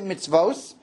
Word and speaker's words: mitzvos: Because if you look at the Torah, yes, we pronounce it mitzvos, but it's spelled B'lashon Yachid, meaning the classic Kri Uksiv.